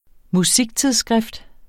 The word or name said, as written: MUSIKTIDSSKRIFT